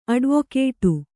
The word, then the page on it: ಅಡ್ವೊಕೇಟು: Kannada